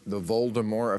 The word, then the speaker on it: Voldemort: Well, you see, those who are familiar with Harry Potter will know the bad guy in these novels is somebody called Voldemort, and you actually pronounce it correctly. The T at the end is silent.